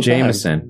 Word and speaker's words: Jamison: I hear it all the Jamison. – Time. Like the whiskey.